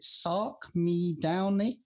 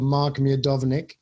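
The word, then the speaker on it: Mark Miodownik: Is it a silent M? Is it Sark Miedownich? – No, it's a guy called Mark Miedownich.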